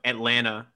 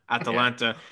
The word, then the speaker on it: Atalanta: Hey, Joe, I'll give you credit, too, after that mispronouncing of Atlanta, Atalanta.